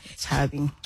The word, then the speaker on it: Chuby: And it's spelled C-H-U-B-Y. – It's C-H-U-B-Y. – Yes. – Yeah, Chubby.